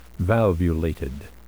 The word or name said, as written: valvulated